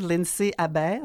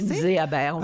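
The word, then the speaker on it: Lindsay-Abaire: surtout de parachute libre de David Lindsay Aber. C'est comme ça qu'il faut le prononcer. – Lindsay Aber, un Américain.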